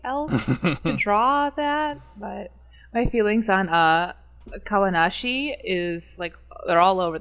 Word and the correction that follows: Kawanishi: Is that how they say his name? Kawanishi yeah.